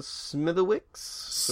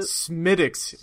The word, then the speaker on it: Smithwick's: I'm drinking Smithwick's, or however you pronounce it. – Smithwick's. You're Irish, bro. Get yeah, whatever. – Shit together. – Smithwick's. – My God. – Well, it's spelled out like Smith.